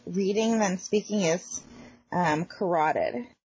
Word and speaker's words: carotid: That, I took anatomy and physiology, so I think you're right there, carotid.